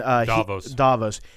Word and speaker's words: Davos: And so Stannis is in his quarters having a conversation with, I believe you pronounce his name, Davos. And Davos.